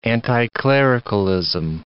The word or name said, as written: anticlericalism